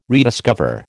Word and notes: rediscover: American English

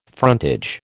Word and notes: frontage: American English

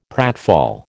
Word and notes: pratfall: American English

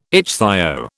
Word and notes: ichthyo: British English

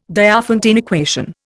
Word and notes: Diophantine equation: Indian

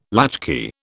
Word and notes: latchkey: British English

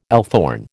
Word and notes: althorn: American English

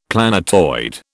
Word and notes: planetoid: British English

